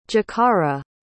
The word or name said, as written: jacarra